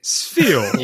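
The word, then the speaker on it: Spheal: Sfeel.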